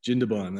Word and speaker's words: Jindabyne: By the way, am I pronouncing Jindabyne correctly? – Jindabyne, that's right, yeah.